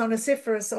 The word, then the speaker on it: Onesiphorus: Tal vez usted no sabe cómo pronunciarlo. Onosíforo. Onesíforo. Onesiphorus. En inglés es onesiphorus o onesíforo.